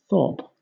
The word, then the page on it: thorp: British English